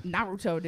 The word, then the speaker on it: Naruto: Naruto, dude.